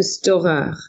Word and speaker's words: Stohrer: That one, if you're going to get it, you have to go to Storer, which is the oldest pastry shop in Paris. That's spelled S-T-O-H-R-E-R.